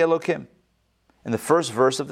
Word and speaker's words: Elohim: But we pronounce it Elo followed by Kim, Elohim, instead of with the hey sound.